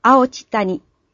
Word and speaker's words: 青地谷: 現在では、青地谷、地は土地の地と書いて、青地谷と呼ばれています。